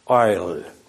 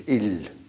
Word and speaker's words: Isle: Isle. Zo ongeveer spreek je het in het Hebreeuws ook uit, hoor. Dus il.